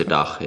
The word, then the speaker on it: DACH: We say DACH, yeah.